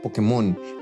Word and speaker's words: Pokémon: La contracción de las palabras se escribe en inglés con tilde, Pokémon, sin embargo, todos pronunciamos Pokémon como palabra aguda. ¿Cómo, debería ser? Bueno, aquí hay, aquí hay una pista a la solución. Se pronuncia Pokémon, pero se acentúa en la E porque de escribirlo sin acento en inglés, la pronunciación cambiaría.